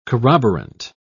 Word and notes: corroborant: British English

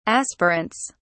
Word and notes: aspirants: British English

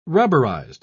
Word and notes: rubberised: British English

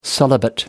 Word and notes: celibate: American English

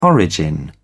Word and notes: origin: British English

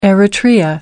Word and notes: eritrea: American English

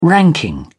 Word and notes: ranking: British English

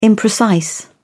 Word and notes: imprecise: British English

